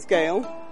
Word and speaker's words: Gail: N-A-N-C-Y. – My name's Gail. – Gail, how do you spell that? – G-A-I-L.